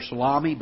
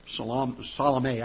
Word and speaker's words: Salome: Some people call her Salami, but it's Salome, I think, is the way it's actually pronounced.